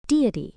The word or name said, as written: deity